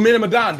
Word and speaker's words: Man of Medan: We talking about Man of, how you pronounce that? Man of Medina. – Medina. – Oh, Man of Medan, the Until Dawn type game.